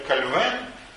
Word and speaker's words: Кальвин: Ну и как сейчас говорят, Кальвин. Правильно говорить, на самом деле, даже Кальвен по-французски, поскольку он был франкоговорящий швейцарец, жил он в Женеве.